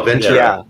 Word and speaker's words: Ventura: Oh no, Ventura. Yeah. T. – Ventura. Okay. – What? Sorry, what? It's a T. Okay. – I say T. Yeah. – Well, and I think most locals say T as well, and I think that's obviously the correct pronunciation.